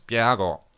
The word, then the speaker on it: Bjerregaard: Bjerregaard, I should actually be able to pronounce it because he's Danish, as am I, so I'm just switching my brain into Danish. Johannes Bjerregaard, there you go.